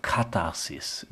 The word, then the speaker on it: Katharsis: sollte das Theater Katharsis, wichtig ist die Betonung auf der ersten Silbe, das wird sehr oft falsch ausgesprochen, das heißt Reinigung bewirken.